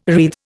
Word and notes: wreathe: Indian

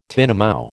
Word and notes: tinamou: American English

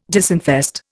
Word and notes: disinfest: Indian

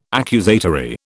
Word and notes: accusatory: British English

